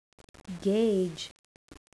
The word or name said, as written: gauge